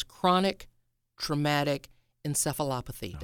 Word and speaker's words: chronic traumatic encephalopathy: Define for us chronic traumatic encephalopathy.